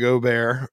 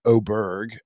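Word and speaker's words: Åberg: Ludvig Aberg, or excuse me, I think we have a new pronunciation. It's Ludvig Aberg, I think is how he wants to be called now.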